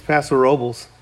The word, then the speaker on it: Paso Robles: Continuing our travel through the Central Coast, we're up in Paso Robles, which we never can get clarification. Paso Robles or Paso Robles? Which one is it? – It's Paso Robles.